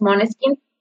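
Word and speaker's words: Måneskin: es Moneskin, se escribe Maneskin, es una palabra eh, danesa que significa como rayo de luna o luz de luna, algo así.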